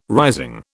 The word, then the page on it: rising: British English